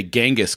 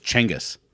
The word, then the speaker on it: Genghis: People say Genghis Khan, and it's not Genghis, it's Chengis. – Yeah, yeah. – It's Chengis Khan,